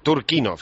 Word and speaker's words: Turchynov: Ahora mismo el presidente en funciones es Alexander Turchynov, o Turchynov, ¿cómo se pronuncia exactamente? Ya te lo pregunto a ti para salir de dudas. – Turchynov, bien, porque lo he oído de todas formas, y de decir de varias maneras.